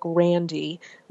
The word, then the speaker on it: Randi: Well, you know, growing up with a name like Randy, which is spelled R-A-N-D-I, which in my book is the female version of that mm-hmm.